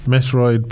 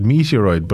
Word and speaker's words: Metroid: I actually I always called it Meteoroid, but I just see it spelled Metroid. Um, so maybe I've been saying it wrong since I was like four years old.